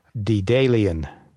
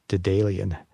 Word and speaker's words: Daedalean: And we see a Zurich-based startup, Dedalian, which is spelled D-A-E-D-A-L-E-A-N, Dedalian.